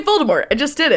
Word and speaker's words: Voldemort: Turns out it's supposed to be a French pronunciation. Voldemort. You just don't pronounce the T. And she says Voldemort.